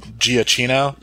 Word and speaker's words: Giacchino: But, like, at the church, and they're all embracing, that's what this movie, it uses, I never know, Michael, it surprised me, I heard how to pronounce it correctly, it's like Giacchino.